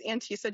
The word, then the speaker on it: Antesa: My name is Antesa Jensen.